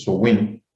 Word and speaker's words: Samhain: nos referimos como Samhain o Samhain, que la pronunciación más correcta es Sowin, bueno, la cual se celebraba el 31 de octubre, primero de noviembre.